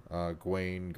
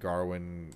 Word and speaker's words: Gawain: And so it's like this very, very crazy retelling of that story, and it's fundamentally, you know, a story about, like, Gawain, Garwin, Gawain, depending on how, you know, like, there's so many different ways they say his name in the movie, right? Because Sir Gawain, yeah. I think is the right term. – No, because it might actually be Garwin, right? – Really?